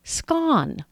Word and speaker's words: scone: I have heard it pronounced scone, like rhymes with Ron.